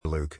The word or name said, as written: Luke